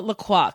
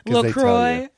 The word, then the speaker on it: LaCroix: And I hate when people call it LaCroix, because I'm like, have you been to the website? LaCroix rhymes with enjoy.